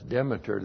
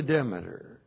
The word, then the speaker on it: Demeter: D-E-M-E-T-E-R. That's Demeter, that is not Demeter. Belonging to Demeter.